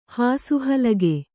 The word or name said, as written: ಹಾಸುಹಲಗೆ